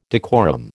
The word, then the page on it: decorum: American English